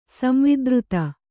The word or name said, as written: ಸಂವಾದಾರ್ಥಿ